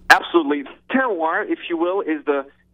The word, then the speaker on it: terroir: if I'm pronouncing this right, but it's terroir, right? – Terroir, perfect pronunciation.